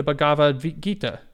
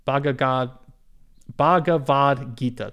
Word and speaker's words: Bhagavad Gita: Read the Bhagavad Gita. Messed it up a little bit, but let's call it the Gita. Bhagavad Gita. There you go. It's important to say it right, so I apologize for that.